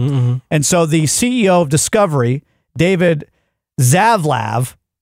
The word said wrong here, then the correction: Zaslav: is that how you say it? – I think he made that up. – Let's call him Dave. – Zaslav. – Ask A.R. Clark. – Oh, he's Zaslav. I powered him off.